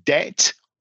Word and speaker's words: debt: but these letters are actually silent. So the T in mortgage is silent, and the B in debt or doubt is also silent.